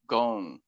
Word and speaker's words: Ghosn: The spelling is kind of weird. It's GHOSN. – Correct. So I've just been saying Gosen and Gon, Carlos Gon.